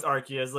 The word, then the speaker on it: Arceus: So, fun fact, it's officially, it's one of the only Pokemon to have an official canon pronunciation because Arce is a term for butt. Like, it's slang for ass in Britain. So the translators flat out said, like, yeah, no, it's Arceus.